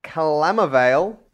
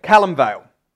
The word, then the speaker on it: Calamvale: This is from Calamavale. No. You've ruined me. Whoever wrote in and said that I can't pronounce things above Byron Bay, you've ruined me. You've gotten in my head. Calamvale.